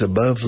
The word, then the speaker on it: above: My love for you is above love. So above rima con love. It rhymes. It rhymes with love. Above. Also it rhymes with dove. D-O-V-E.